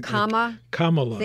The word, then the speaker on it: Kamala: I'm trying to pronounce it correctly. – Kamala. Think of the little kids at the convention.